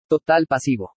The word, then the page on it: TOTAL PASIVO: spanish